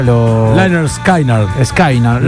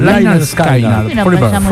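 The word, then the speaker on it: Lynyrd Skynyrd: Liner Skynyrd. – Liner Skynyrd.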